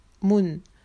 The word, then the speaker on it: Mon: Moon. Moon. Moon. Ça, s'écrit Mon, mais ça se prononce Moon.